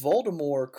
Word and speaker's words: Voldemort: And he pronounces Voldemort correctly in the first 4 books.